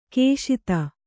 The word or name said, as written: ಕುಶದ